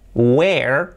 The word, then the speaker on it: where: Where son dos sílabas, we-er, dos sonidos, mejor dicho. We-er, we-er, we y el sonido de R. Where, where, dos sílabas bien separadas.